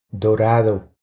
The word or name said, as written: Dorado